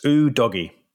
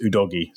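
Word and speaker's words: Udogie: First of all, Destiny has said that his surname is pronounced Ooh Doggy.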